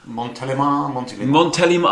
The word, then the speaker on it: Montélimar: That is, it's Montélimar, is that how you pronounce it? – Montélimar.